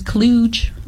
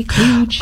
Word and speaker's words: kludge: You search a hard G like kludge? – It says kludge, like a J, kludge. – Oh, with a J? – Yeah.